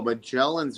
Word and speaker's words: Magellan's: Another sea facility at the harbor is Maglian's Restaurant. – No, Magellan's Restaurant. I tried hard. – I've butchered that.